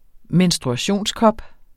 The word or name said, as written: menstruationskop